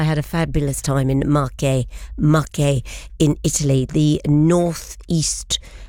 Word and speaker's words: Marche: The correct pronunciation is Marche.